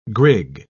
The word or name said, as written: grig